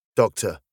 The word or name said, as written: doctor